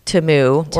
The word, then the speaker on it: Temu: Temu, I'm probably saying it wrong because it's Temu, which I think that they shocked a lot of people.